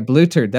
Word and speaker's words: bluthered: Oh, bluthered. – Yeah, bluthered. That's how you say it.